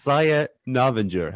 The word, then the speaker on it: Novinger: And if I don't get this name right after all we've talked about, it's Saya Novinger.